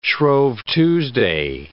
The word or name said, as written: Shrove Tuesday